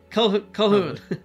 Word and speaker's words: Calhoun: How do you say it? Calcoon? I'm going to say it wrong every time. Played by Robert Carlyle. He shows up. – Just say Calhoun. – Calhoun. – Yeah, F.W. Calhoun.